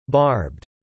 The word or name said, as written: barbed